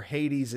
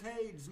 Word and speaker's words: Hades: It's Hades, not Hades. – Well, technically speaking, by that definition, no one pronounces any Greek god name correctly because we're using the anglicized versions of their names.